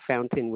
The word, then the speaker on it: Fountain: M-O-N-I-Q-U-E? – Correct. – And you say your last name was Founding, F-O-U-N-D-I-N-G? – No, it's Fountain, like water fountain with an E at the end. – Oh, F-O-U. – N-T-A-I-N-E.